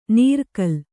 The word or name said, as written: ನೀರ್ಕಲ್